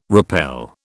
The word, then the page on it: repel: British English